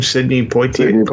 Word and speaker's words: Sidney Poitier: Sidney Poitier? – Yeah.